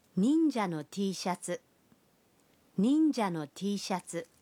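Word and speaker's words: টি শাটসু। (Tシャツ): টি শার্ট শব্দটির জাপানি উচ্চারণ হল টি শাটসু।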